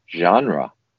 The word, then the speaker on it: genre: First of all, it's pronounced genre. – Genre. – And it's spelled G-E-N-R-E. Okay? So it's the French word, obviously.